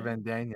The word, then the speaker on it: Van Dien: How do you say his last name? Van Dien? – Casper Van Dien.